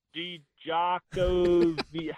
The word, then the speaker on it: Djokovic: The second honorary JoCo of the week goes to, let me see if I say this right, Novak Djokovic. How you say it? – That's Djokovic. – Close Djokovic. Enough. Close. I think you said the D, Djokovic.